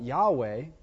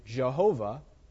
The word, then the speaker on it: Yahweh: So they put YHWH. Okay, and the reason why we call it Yahweh, or the German way of pronouncing it, Jehovah. You guys heard of Jehovah's Witnesses? That's why they call themselves Jehovah's Witnesses, because that's the covenant name of God. They say Jehovah or Yahweh.